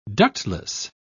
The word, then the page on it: ductless: British English